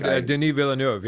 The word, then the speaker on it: Denis Villeneuve: Denis Villeneuve, yeah.